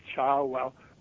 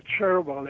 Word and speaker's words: Cherwell: And, of course, then he got the title of Lord Charwell, which you pronounce Charwell or Cherwell in American, because the Char was a brook that flows through Oxford.